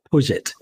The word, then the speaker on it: Puget: By the way, is it Puget or Puget? – Puget. – Puget? Mm-hmm. Oh, yes. I've been trying to pronounce it like it was French. Oh, as I say, it's not a French company.